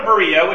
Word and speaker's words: Berea: Well, that's the Greek pronunciation. So Berea. So they go to Berea, which is a much smaller town, and it's a day's walk to the west.